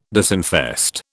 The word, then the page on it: disinfest: British English